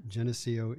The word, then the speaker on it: Geneseo: Geneseo, Illinois.